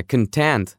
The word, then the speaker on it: content: А еще стоит сказать, что прилагательное content — это довольный, удовлетворенный, тоже часто на слуху. Ударение, как и у глагола, на второй слог.